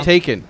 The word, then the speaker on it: Taycan: The Taycan. Is that it? How do you pronounce it? Do we know? – Taken.